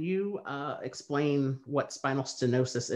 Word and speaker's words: stenosis: um, so you were diagnosed with spinal stenosis.